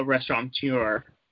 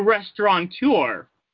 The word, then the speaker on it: restaurateur: William Stamos is also a restaurateur. That's not how you say it. A restaurateur.